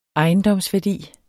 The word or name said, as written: ejendomsværdi